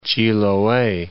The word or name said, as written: Chiloe